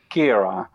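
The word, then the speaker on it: Ciara: Eh, ja, de uitspraak, is een, eh, dit is een Ierse eh, naam, was, was een inzending van de Ieren. En dat moet, wat ik heb begrepen in ieder geval, ik ben ook geen taal-expert, is het Kera. Zo moet je het uitspreken.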